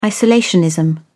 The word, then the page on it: isolationism: British English